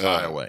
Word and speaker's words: Iowa: Iowa is a part of Louisiana that's spelled like Iowa. – Yeah. – But like with everything else in Louisiana, it's not fucking pronounced the way you'd think it is. – Right. – And it's Iowa.